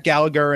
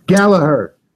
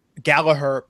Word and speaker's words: Gallagher: but we had a match between Jack Gallagher and Ariya Daivari. – Gallagher. – Gallagher, pardon me. Uh, and yes, they did uh, make it a point of pronouncing that.